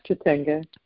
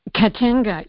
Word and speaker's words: Katenga: And then I found this, now I don't know exactly how to pronounce this, the material that... – Katenga. – Katenga. – Yeah, perfect. You got it.